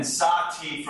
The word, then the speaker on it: sahti: And by the way, our version is spelled S-A-H-T-E-A, so that the word tea is in there. But traditionally, there is a beer called SahTea, S-A-H-T-I.